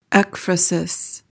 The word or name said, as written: ekphrasis